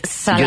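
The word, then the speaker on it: Szalay: Pues me traje un libro que se llama La carne, que es de David Snarsen. Ahora pronunciaré bien su nombre. David Salai.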